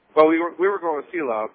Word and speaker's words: Selah: That's just the way it's always been pronounced by every person I've ever heard read it. I believe it's Selah.